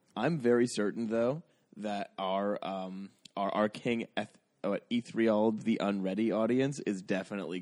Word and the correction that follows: Ethelred: Yeah, but you've lost them by pronouncing Ethelred Ethriald.